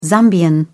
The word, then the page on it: zambian: British English